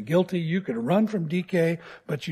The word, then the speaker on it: Dike: Her name transliterated into English would be spelled D-I-K-E. We'd probably call her Dike. It was pronounced DK, which actually rhymes with Nike, which is actually pronounced Nike.